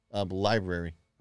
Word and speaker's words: LBRY: Library. – Okay. – Library or Odyssey. It's spelled RB, I mean LBRY.